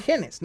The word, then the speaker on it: gene: como si fueran genes, ¿no? Ahora, eh, la palabra meme en este caso viene viene de, la palabra gen en inglés que se escribe gene, ¿sí? que se pronuncia gen, y, de, de, la palabra memoria, eh, que creo que era latín, que se, escribía mimeme, que significa imitar.